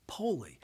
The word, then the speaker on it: pole: It's spelled P-O-L-E, just like our English word pole. However, in Swahili, it's pronounced poli.